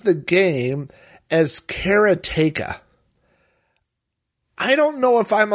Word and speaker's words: Karateka: Now, speaking of pronunciations, everybody I know has pronounced the name of this game as Karateka.